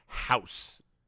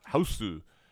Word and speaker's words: Hausu: House. It's actually pronounced Hausu.